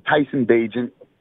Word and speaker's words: Bagent: I just want to say I agree with everything you say except for Tyson Bagent's name. – Uh-oh. How did I butcher it? – It's Tyson Bagent. – Bagent. Tyson yep. Bagent. I'm sorry yep. to the Bagent family.